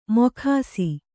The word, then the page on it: ಮೊಗಸು: mogasu